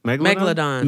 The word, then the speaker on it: Megalodon: Megalodon.